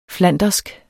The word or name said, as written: flandersk